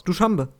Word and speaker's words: Duschanbe: Wie spricht man das aus? Duschambe. Ja, würde ich schon sagen. Es wird auf Deutsch D-U-S-C-H-A-N-B-E geschrieben.